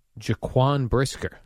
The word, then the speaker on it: Jaquan Brisker: I love Bear safety, Jaquan Brisker.